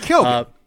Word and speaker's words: Keoghan: It's Keoghan. It's got to be Keoghan. – Keoghan. I think it. I've heard Fennessy say Keoghan. I've heard Adnan Verk say Keoghan.